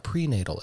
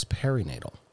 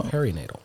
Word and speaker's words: perinatal: So, and sorry, I mispronounced it. It's not prenatal, it's perinatal. – Oh. – Perinatal.